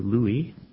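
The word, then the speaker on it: Lui (לוי): Now Korah, the son of Izhar, the son of Kohat, the son of Levi, or as it's pronounced in Hebrew, Lui, with Dathan and Abiram, the sons of Eliab, and On, the son of Pelet, sons of Reuben, took men.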